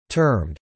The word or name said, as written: termed